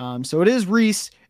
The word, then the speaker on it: Ries: Preston Reese, his name is spelled Rise, R-I-E-S. This one, I went on YouTube, I scoured YouTube, I found different podcasts, I found different interviews. It is pronounced Reese, and I am 100% confident in that.